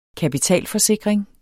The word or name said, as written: kapitalforsikring